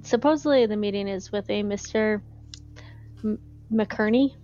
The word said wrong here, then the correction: Kinnery: Did I say that right, Mike? – Thomas Kinnery. – I totally So you mauled that name. – Totally mauled it. – Kinnery, said? – Kinnery. Yes, and since Jack can't show his face around there, apparently I may have to end up taking Mr. Forsyth with me.